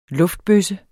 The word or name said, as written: luftbøsse